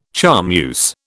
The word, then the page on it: charmeuse: British English